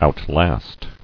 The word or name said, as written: Outlast